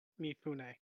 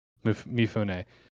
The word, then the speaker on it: Mifune: Toshiro Mifune. – Mifune.